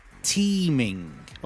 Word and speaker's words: teeming: Another one is it's teeming. Okay, not team as in T-E-A-M. Teeming is spelled T-E-E-M-I-N-G. It's teeming. It's teeming.